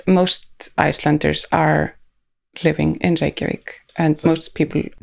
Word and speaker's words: Reykjavik: Now I'm self-conscious about Reykjavik.